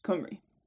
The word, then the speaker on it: Cymru: they're spelt slightly differently, but they're both pronounced Cymru.